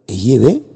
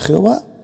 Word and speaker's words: Yahvé: Yahvé, que parece ser que esa es la pronunciación correcta en hebreo arcaico. Otros dicen que es Jehová, otros dicen que es Yahweh, a mí me da igual.